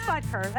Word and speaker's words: Butker: Yeah, it is Butker.